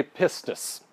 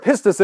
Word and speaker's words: pistis: It's literally pistis, all right? That's how this word is pronounced.